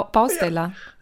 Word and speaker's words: Bausteller: Wie ba Baustelle? Bausteller. – Also so wie Stella, nur mit ba Bausteller.